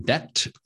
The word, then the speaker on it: debt: Okay, number seven, debt. So remember the silent B.